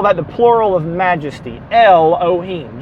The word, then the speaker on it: Elohim: Elohim, which is Aleph, Lamed, He, Vav, Mem, or E-L-O-Y-M, or E-L-O-H-Y-M, Y-M, Y-M, I sounded so southern, Elohim, all right? We spell that in English, E-L-O-H-I-M, Elohim.